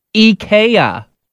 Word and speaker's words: IKEA: I guess it's actually pronounced IKEA.